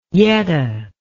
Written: yetta

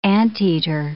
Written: anteater

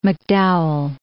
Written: MacDowell

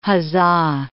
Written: huzzah

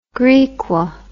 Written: Grikwa

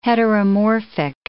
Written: heteromorphic

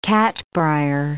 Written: catbrier

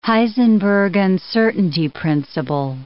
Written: Heisenberg uncertainty principle

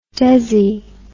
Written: desi

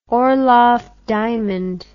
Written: orloff diamond

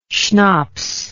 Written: shnaps

